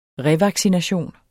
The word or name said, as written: revaccination